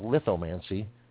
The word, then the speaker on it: Lithomancy: It's called Lithomancy, The Psychic Art of Reading Stones. And for those of y'all that are spelling challenged, it's Lithomancy. And it's pronounced lithomancy, like lithograph.